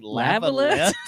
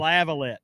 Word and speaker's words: Lavalette: Lavalette, West Virginia. – Did you just say Lavalette? – That's how they say it. They do not say lava.